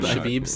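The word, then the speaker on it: Shebib: How do you pronounce his last name? – Didn't look that up. – Shabib. – Noah Shabib. – Shabib sounds right. – That sounds right.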